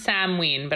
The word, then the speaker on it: Samhain: I think it's pronounced Samhain, but I could be wrong.